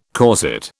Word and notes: corset: British English

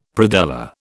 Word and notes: predella: British English

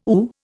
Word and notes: ooh: Indian

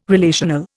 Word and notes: relational: Indian

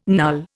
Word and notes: null: Indian

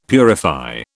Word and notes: purify: British English

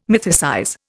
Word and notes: mythicize: Indian

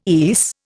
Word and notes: ESE: Indian

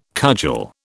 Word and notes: cudgel: British English

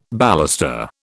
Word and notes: baluster: British English

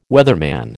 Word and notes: weatherman: American English

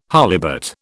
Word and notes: holibut: British English

